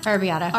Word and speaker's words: Arrabbiata: Arrabbiata. How do you say that? – Arrabbiata. – Arrabbiata yeah.